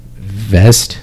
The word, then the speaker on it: Vezt: Um, he invested in a blockchain music app, v- Vest, I guess it's pronounced V E Z T.